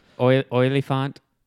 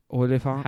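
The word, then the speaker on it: Olyphant: Oilyfont. – Oliphant.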